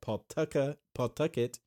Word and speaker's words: Pawtucket: He was also a hitting coach at Pawtucket is how you say that word.